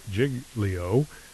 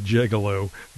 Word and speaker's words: Giglio: The Italian luxury liner Costa Concordia ran aground off the Tuscan island of Giglio, and almost said Gigolo, Giglio. It kind of looks like that, the way it's spelled.